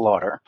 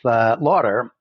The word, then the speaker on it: Laughter: I took on her name and together we dropped the S and spelled L-A-U-G-H-T-E-R, at which my mother decided to pronounce Lauder.